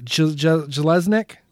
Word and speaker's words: Jeselnik: His last name is spelled J-E-S-E-L-N-I-K. Jelesnik.